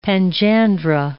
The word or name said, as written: panjandra